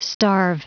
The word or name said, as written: starve